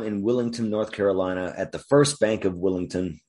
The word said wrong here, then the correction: Wilmington: Wilmington, I keep saying it wrong, none other than Stephen King himself tries to withdraw some cash, but the ATM, it just calls him an asshole.